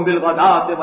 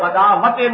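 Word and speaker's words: بالغداة: وَاصْبِرْ نَفْسَكَ مَعَ الَّذِينَ يَدْعُونَ رَبَّهُمْ بِالْغَدَاةِ وَالْعَشِيِّ، یہ غدات ہے، غداوتیں نہیں ہے، واو جو ہے یہاں پر بالکل اس کا کوئی تلفظ نہیں کیا جائے گا، یہ صرف لکھنے میں آ رہی ہے.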